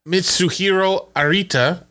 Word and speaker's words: Mitsuhiro Arita: Mitsuhiro Arita.